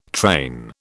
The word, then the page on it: train: British English